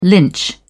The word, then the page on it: lynch: British English